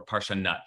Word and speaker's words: Parshanut: It's called Parshanut, or Parshanut, actually, as is the way it's usually pronounced. I mean, the people usually pronounce it.